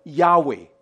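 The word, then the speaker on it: Yahweh: 既然是子音，很多人都不知道怎么发音。但是犹太人历代以来他们都懂得叫亚伟，叫这个叫亚伟。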